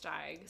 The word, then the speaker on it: Steig: The flute solos, played by the Pied Piper, were played by Jeremy Steig. I think that's how you pronounce it. – I think it's Steig.